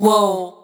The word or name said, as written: Wow